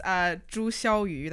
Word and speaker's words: Zhu Xiaoyu: My name is Zhu Xiaoyu. That's like the Chinese pronunciation technically.